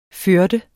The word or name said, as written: førende